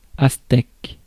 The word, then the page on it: aztèque: [as.tɛk]; /az.tɛk/